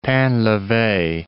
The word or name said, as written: Painleve